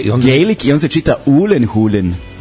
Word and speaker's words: Gyllenhaal: I onda imaš njegove klipove na YouTube-u gde kaže kako se preizgovara tvoje i prezime i onda je on rekao Ulenhulen. – Ulenhulen, tako se izgovara kao Gaelic.